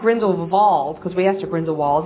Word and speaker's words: Grindelwald: I was wondering what you guys thought about Grindelwald. – Mm-hmm. – And notice that the W is pronounced V as in German pronunciation.